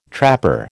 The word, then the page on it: trapper: American English